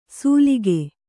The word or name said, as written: ಸುಲಿಕೆ